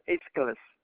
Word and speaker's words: Aeschylus: There was a Greek the Greek playwright in the 6th century BC almost impossible to spell his name, and it's not easy to pronounce it, but it's Aeschylus.